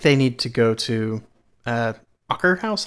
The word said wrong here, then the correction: Akershus: How do you say it? I always... Akershus. – I mean, I don't know how it's pronounced in Norwegian, but Akershus.